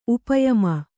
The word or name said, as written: ಉಪಯಾಮ